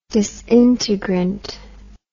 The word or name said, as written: disintegrant